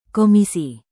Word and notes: komisi: Indonesian

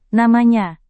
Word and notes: namanya: Indonesian

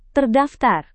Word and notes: terdaftar: indonesian

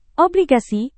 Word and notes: obligasi: Indonesian

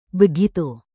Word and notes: begitu: indonesian

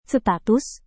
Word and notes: status: Indonesian

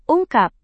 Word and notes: ungkap: Indonesian

